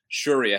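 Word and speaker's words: Syuri: I keep on saying Suri, and it's S-Y-U-R-I, which is Suri.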